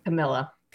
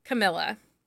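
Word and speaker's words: Kamilah: How do you say her first name? – Camilla. – Camilla.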